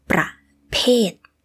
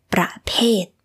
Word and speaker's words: ประเภท: this word pronunciation is a little similar to ประเทศ which means country But it is different. For the word type, it is ประเภท. The second syllable is p, p, with the p, ประเภท. But for the country, it is ประเทศ. The second syllable starts with the sound like t in English.